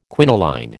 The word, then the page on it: quinoline: American English